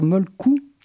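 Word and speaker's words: Mölkky: Pour le découvrir à Montpellier, c'est le moment, car le premier tournoi de, on verra comment ça se prononce, ah, je veux dire Molkou ah voilà, merci. Euh, bien que cela ne s'écrive avec un Y à la fin, est organisé ce dimanche 29 mai, c'est-à-dire demain, de 9h30 à 18h30.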